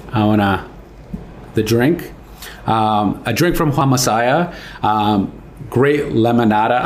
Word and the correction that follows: Limonana: I always say it wrong. – It's limonana. Limonana.